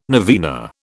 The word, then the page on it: novena: British English